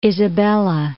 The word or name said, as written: Isabella I